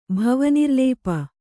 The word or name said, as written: ಭವನಿರ್ಲೇಪ